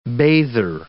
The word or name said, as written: bather